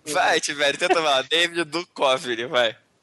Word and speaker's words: Duchovny: Como é que fala o nome dele? – Duchovny. – Duchovny, cara. – Duchovny? – É, Duchovny. – Duchovny. – Lembra de OVNI, Arquivo X, OVNI, Duchovny.